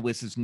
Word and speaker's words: Wiss: But Tim is interviewing the director of Amanda Wiss's new movie, The Id. It's Tommy Hudson. And he keeps mispronouncing Amanda Wiss's last name. And Tommy Hudson says, it's not Weiss, it's Wiss.